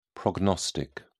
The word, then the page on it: prognostic: British English